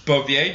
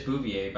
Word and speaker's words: Bouvier: Yeah, because I was just thinking, because I pronounced it wrong earlier. It is Bouvier, but I thought it was Bouvier.